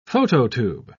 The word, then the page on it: phototube: British English